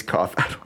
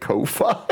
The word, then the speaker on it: Ko-fi: Is that how you pronounce that? I thought it was coffee. Like, buy me a coffee. – Yeah, you're right. It is coffee. Why do I keep calling it Ko-Fi?